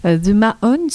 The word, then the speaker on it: The Mahones: The Mahones.